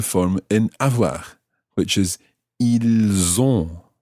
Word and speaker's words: ils ont: So that's I-L-S. And the verb itself is O-N-T. So what you end up with is pronouncing the S of the il in that one because it's following, or it's followed by a vowel.